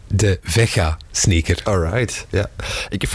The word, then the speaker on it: Veja: Nee, zij, zeggen Vega op zijn Frans. Ah, dus het zit in de Romaanse talen, dus het is helemaal niet Spaans. Het is Portugees, het is een Frans merk ook, eh, wat de mishmash, wat...